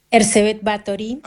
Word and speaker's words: Erzsébet Báthory: y desde ya decime cómo se pronuncia el nombre. – Erzsébet Báthory. – Ah, lo pronunciaste muy bien.